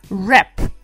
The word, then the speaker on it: wrap: Rap прекрасное слово, которое все всегда неправильно произносят, потому что там спряталась буква W, которая не произносится в самом начале. Wrap. Пишется W-R-A-P.